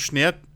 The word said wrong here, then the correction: Schwert: Ähm, das ist ein zweischneidiges Schnert oder ein Kompromiss, den ich, ich habe schon wieder falsch gesagt, ich kotze. Es ist ein zweischneidiges Schwert.